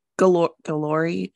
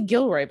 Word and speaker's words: Guillory: And Jasmine Guillory. – I always say Gilroy, but I don't know why I think that's right. – That's not how that's spelled.